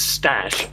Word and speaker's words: stache: Well, I would pronounce STACHE is stash, as in an abbreviation of mustache, but I'm not sure if that's what this person meant.